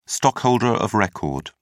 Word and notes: stockholder of record: British English

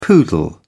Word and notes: poodle: British English

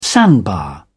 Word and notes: sandbar: British English

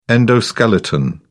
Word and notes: endoskeleton: British English

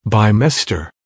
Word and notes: bimester: American English

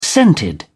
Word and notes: scented: British English

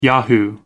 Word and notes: yahoo: American English

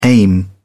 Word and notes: aim: British English

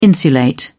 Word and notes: insulate: British English

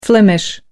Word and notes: flemish: American English

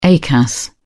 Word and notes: acas: British English

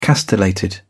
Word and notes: castellated: British English

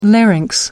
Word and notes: larynx: American English

